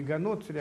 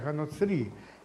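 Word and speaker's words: Га-Ноцри: Ну, во-первых, там он произносит неправильно, не «ганоцри», а «ганоцри». Это значит, надо говорить, и в семитических языках обычно последний слог делает ударение.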